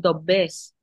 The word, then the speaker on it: doblers: Dinero, en catalán se dice dinés, diners, pronuncian la R, nosotros no y nosotros decimos doblés, que se escribe doblers, ¿no?